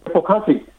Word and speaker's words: Bokashi: Bokashi Bokashi。 – 对，因为这个就是日文的发音了，因为是日本人发明的，所以它一发音的也是日本文。